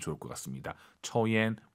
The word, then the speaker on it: 抽烟: 우리가 초하지 마시고 오유는 어우 발음이에요. 抽 초옌.